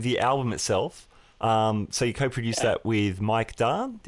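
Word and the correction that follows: Daan: Did I pronounce that? – Yeah. Mike Dane, actually. D-A-A-N.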